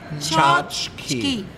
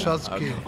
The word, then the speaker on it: tchotchke: Okay, how, how do you pronounce it? – No T. – Chotchki. Chotchki. – Okay. Chotsky.